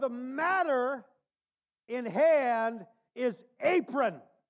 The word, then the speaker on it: aphron: The Greek word for foolish in 1 Peter 2:15 is spelled A-P-H-R-O-N, but it's pronounced like this cooking garment, apron.